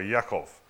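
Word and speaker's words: Yaakov (יעקב): After this, Esau's brother Jacob came out with his hand grasping Esau's, ho- Esau's heel so he was named Jacob or Yaakov uh, in their pronunciation.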